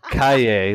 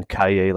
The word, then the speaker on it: Cailler: And then and then Cailler, like Yippie Cailler.